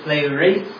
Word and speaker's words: pleroo: So when you go to the actual root word behind fulfilled, it actually leads you to pleroo, is how it's pronounced.